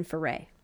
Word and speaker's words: Foray: And we wonder if maybe it was pronounced Foray, but spelled differently. It's spelled F-O-R-E-R. But in interviews, she said that she was born June Foray.